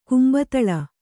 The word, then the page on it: ಕುಂಬತಳ: Kannada